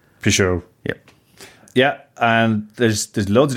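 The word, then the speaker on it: Peychaud: Pichot.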